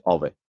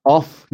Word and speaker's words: of: الان حرف اضافهش آوه. تلفظش هم آف نیست بچه ها. آف اینه دابل اف. دوتا اف که میگیم آفه. یه دونه اف آوه. یعنی میگیم او وی. آو. اوکی؟